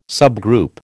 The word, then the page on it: subgroup: American English